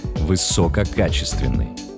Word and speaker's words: высококачественный: Только на «а». Многие ставят ударение на первое «о». Ошибку эту иногда делают даже корреспонденты, дикторы и радиоведущие. Порой с ударением на «о» это слово произносят и самые образованные люди. Но это неправильно. Орфоэпический словарь русского языка как государственного разрешает только один вариант. Высококачественный. Только так.